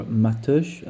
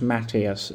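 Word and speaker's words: Mateus: is Matthias, or Matush, I believe it's, or Matus. It's not pronounced Matthias, as everyone likes to say over here in the UK, but nevertheless, that was the trigger.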